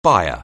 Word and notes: buyer: anglais

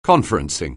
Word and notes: conferencing: anglais

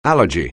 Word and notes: allergy: English

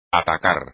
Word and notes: atacar: espagnol

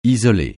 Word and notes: isoler: francais

